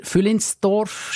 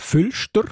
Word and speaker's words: Füllinsdorf: Füllinsdorf spricht man aber gar nicht so aus, gell? Wenn man Nein, in Basel bin jetzt, sagen wir Füllstorf.